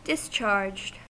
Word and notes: discharged: American English

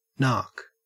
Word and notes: nark: Australian English

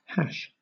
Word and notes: hash: British English

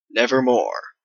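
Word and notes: nevermore: Canadian

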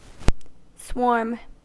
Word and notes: swarm: American English

